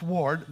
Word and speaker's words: weard: And ward, W-E-A-R-D, pronounced ward, that is the word for, of course, ward, which means keeping.